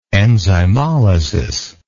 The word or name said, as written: enzymolytic